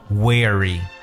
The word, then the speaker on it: weary: 譬如说，我们把这个词 w e a r 如果加上一个 y，就变成了形容词，它的读音呢就叫做 weary。